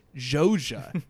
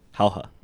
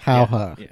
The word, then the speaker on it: Jauja: So the films we're talking about are, well, for timeline B, I'll say first, a film that I thought was pronounced Jauja, but is actually pronounced... – Hauja. – Hoja. Hauja. – Hauja. It's Hauja.